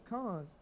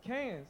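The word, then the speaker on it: Cannes: And I'm like, no, nigga, it's whatever I call it. – It's not. It's y'all both saying it wrong. It's French, and y'all both saying it wrong. – How do you say it? – It's con.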